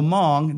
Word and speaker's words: Hmong: Now, the Hmong, that's spelled H-M-O-N-G, it's an ethnic group, the H is silent.